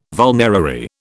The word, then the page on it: vulnerary: British English